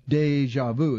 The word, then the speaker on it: DjVu: Now, it's abbreviated DJVU, Deja Vu is how it's pronounced.